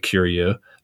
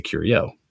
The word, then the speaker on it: Curieux: It's spelled C-U-R-I-E-U-X. And if you were going to say it the French way, it would probably be more like Curio, but we say Curio for whatever reason.